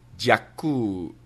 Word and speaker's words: дякую: Okay, it's not spasibo. It's дякую. I hope my pronunciation was okay. Дякую. Thank you. In the Ukrainian language.